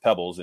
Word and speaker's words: Peebles: You know, and for all my life, I mean, my, you know, my name is spelled Peebles, but it's pronounced Pebbles.